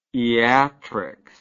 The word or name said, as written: iatrics